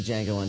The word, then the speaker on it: Django: I just saw The Django Unchained. I thought it was pretty good. – The D is silent.